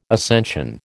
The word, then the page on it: ascension: American English